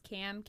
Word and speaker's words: Kam: You can either find me on Nick's page or it's just Cam, K-A-M, Renee, R-E-N-E-E, Fit.